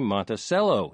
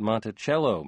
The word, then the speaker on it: Monticello: Folks in Arkansas say Monticello, not Monticello, Mr. Goss wrote, and he added, go Boll Weevils.